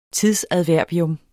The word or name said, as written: tidsadverbium